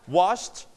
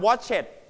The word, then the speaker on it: washed: washed repeat after me wash brush crash เสียง s s จะเสียงยาวนะครับ so when you put ed you pronounce watch it ไหมครับ watch it ไหม watch it ไหมไม่นะครับห้ามออกเสียงว่า watch it เด็ดขาด so what how to pronounce this word wash with ed we pronounce t